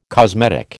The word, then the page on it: cosmetic: American English